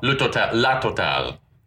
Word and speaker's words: La Totale: Called Le Totale. Is that right? How do you say it? Le Totale? – La Totale.